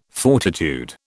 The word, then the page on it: fortitude: British English